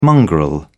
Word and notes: mongrel: British English

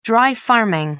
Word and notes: dry farming: American English

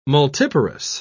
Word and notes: multiparous: British English

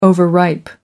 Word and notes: overripe: American English

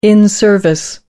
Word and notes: in service: American English

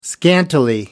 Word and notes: scantily: American English

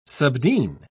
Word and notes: subdean: British English

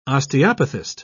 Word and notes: osteopathist: British English

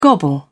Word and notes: gobble: British English

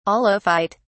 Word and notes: aulophyte: British English

